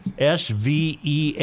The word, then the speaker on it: Svea: How do you pronounce S V E A Minnesota? Sve.